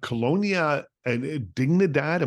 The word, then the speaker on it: Colonia Dignidad: But Colonia and Dignidad, am I saying that correctly? – Yes.